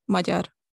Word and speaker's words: Magyar: Magyar.